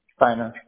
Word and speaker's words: Steiner: Steiner. S-T-E-I-N-E-R. – Got it. Steiner. And first name, do you spell that S-A-N-D-R-O? – Correct.